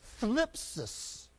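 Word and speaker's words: Thlipsis (θλῖψις): It is two syllables. Thlipsis. That's spelled, if we transliterate, T-H-L-I-P-S-I-S. Can you even say it?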